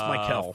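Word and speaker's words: Mykel: I believe Mikel Williams, I think is how you say it. – Williams. – I believe it's Mikel.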